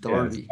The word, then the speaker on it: derby: Spelled derby, but I think they pronounce it derby.